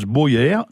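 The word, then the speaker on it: boièr: D'ailleurs, en occitan, le bouvier se prononce Boyère, B-O-I-E, accent grave R, ou B-O-E, accent grave R.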